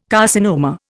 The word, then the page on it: carcinoma: Indian